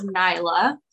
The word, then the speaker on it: Naila: My first name is Nyla. So it's spelled N-A-I-L-A, but you would pronounce it as N-Y-L-A.